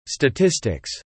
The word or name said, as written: statistics